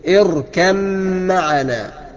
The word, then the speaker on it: اركب معنا: وكلمة اركب معنا ما تقلقلهاش وتنطق الميم لوحدها ولكن دخل الباء، ادغم الباء في الميم وقول كده اركب معنا، يا بني اركب معنا ولا تكن مع الكافرين.